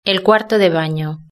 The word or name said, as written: el cuarto de baño